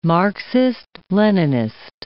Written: Marxist-Leninist

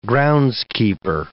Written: groundskeeper

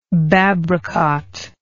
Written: babracot

